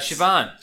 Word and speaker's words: Siobhan: Oh, it's you know it's Irish. Uh, you know, uh, Siobhan, Siobhan, but it's Siobhan.